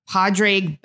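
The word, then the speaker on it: Pádraig: His name is, I think you pronounce it Padraig. Is that how you pronounce it? – He's an Irishman named Padraig. Yeah.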